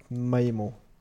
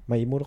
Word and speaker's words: Maemo: Und Maemo. Mit Ä? – Mit Ä. – Maemo. – Maemo. – Ja, wir sind hier Deutsch, da kann man das schon mal Deutsch aussprechen. – Ja, mach ich doch. Maemo. – Nee, ich hätte Maemo ausgesprochen. – Ja. – Weil A ist ja Ä.